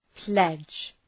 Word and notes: pledge: pledʒ

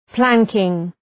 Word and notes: planking: ‘plæŋkıŋ

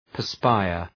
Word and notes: perspire: pər’spaıər

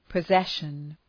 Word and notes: possession: pə’zeʃən

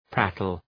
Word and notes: prattle: ‘prætəl

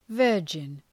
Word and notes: virgin: ‘vɜ:rdʒın

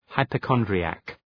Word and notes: hypochondriac: {,haıpə’kɒndrı,æk}

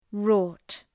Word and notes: wrought: {rɔ:t}

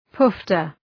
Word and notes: poofter: ‘pʋftər

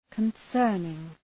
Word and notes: concerning: {kən’sɜ:rnıŋ}

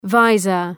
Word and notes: visor: ‘vaızər

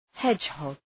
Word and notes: hedgehog: ‘hedʒhɒg

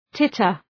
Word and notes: titter: ‘tıtər

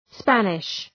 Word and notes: Spanish: ‘spænıʃ

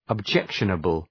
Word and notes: objectionable: əb’dʒekʃənəbəl

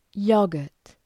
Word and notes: yogurt: ‘jəʋgərt

